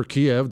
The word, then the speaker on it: Kyiv: Kyiv aside, or Kiev, depending on how you say it, Ukrainian cities are rubble.